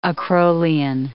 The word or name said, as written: acrolein